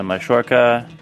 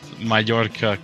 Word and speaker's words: Mallorca: Marash Kumbula just went on a loan to Mallorca. So he can go clubbing. Oh my god, the Argentinian pronunciation. Mallorca, come on. You're not in Europe.